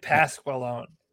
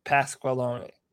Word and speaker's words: Pasqualone: The Carolina Panthers, hired Paul Mascaloni. I'm pretty sure that, I'm not pretty sure if that's pronounced properly. – I've been saying Pasqualone. How it reads phonetically. Pasqualone.